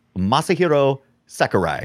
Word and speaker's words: Masahiro Sakurai: Most of it was designed by, I'm going to say his name wrong, but Masahiro Sakurai.